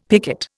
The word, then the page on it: picket: Indian